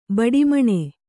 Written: ಬಡಿಮಣೆ

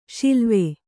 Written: ಷಿಲ್ವೆ

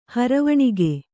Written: ಹರವಣಿಗೆ

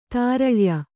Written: ತಾರಳ್ಯ

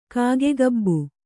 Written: ಕಾಗೆಗಬ್ಬು

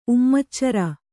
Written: ಉಮ್ಮಚ್ಚರ

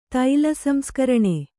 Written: ತೈಲಸಂಸ್ಕರಣೆ

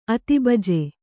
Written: ಅತಿಬಜೆ

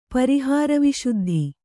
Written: ಪರಿಹಾರವಿಶುದ್ಧಿ

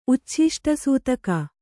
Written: ಉಚ್ಛಿಷ್ಟಸೂತಕ

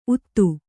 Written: ಊದ

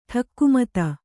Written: ಠಕ್ಕುಮತ

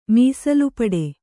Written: ಮೀಸಲುಪಡೆ

